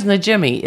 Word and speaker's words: Najimi: Because her name is Najimi. It's, you say it like it looks. It's not like some weird... – It's a weird name, but it's pronounced phonetically.